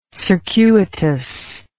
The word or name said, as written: circuitousness